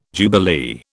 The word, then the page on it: jubilee: British English